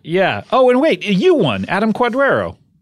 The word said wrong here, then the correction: Cordero: It's actually Cordero. – Is it Cordero? – Not a big deal, but just like pronounce it right.